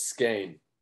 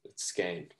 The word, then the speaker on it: Skane: Yeah, so it's Skane. Like you said, which is kind of confusing because it's not at all what it looks like, but yeah, it's Skane.